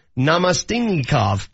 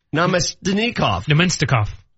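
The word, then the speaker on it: Namestnikov: I butchered the hell out of that. If anyone could properly pronounce that, please help us out. Let me try that again. Vladislav Namastinikov. – Namastinikov. – Good job, man.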